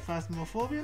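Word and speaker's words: Phasmophobia: Es que, eh, la, bueno, es que la primera noticia es que Fas, Fasmofia, no sé si así se pronuncia bien o Fasmofobia, ¿no? – Fasmofobia.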